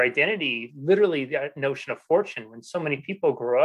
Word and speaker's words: Forchione: So they changed the pronunciation of Forchione, but the Italian way to say it is Forchione.